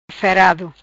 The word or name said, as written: ferado